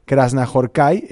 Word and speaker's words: Krasznahorkai: No sé si la H en húngaro es silenciosa, digo, perdón, en húngaro se pronuncia Krasznahorkai, en español me imagino pues que es silenciosa, pero lo importante más que el apellido es la obra extraordinaria, ¿no?, de este hombre.